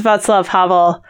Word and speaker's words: Václav Havel: So we think they pronounce Václav Havel the way I do, for better or for worse, which I think is correct. I've looked it up, and also Clafouti, we think, or you know, something like that.